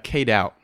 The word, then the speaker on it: K-Doubt: K-Doubt. – K-Doubt. How do you spell that? – K-D-O-U-B-T.